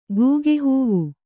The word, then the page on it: ಗೂಗೆಹೂವು: Kannada